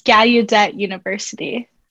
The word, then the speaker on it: Gallaudet University: And you're at Gallaudet University. Did I say that right? – Yes, it's Gallaudet University.